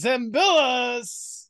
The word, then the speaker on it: Zembillas: Welcome to the podcast, Charles Zembillas.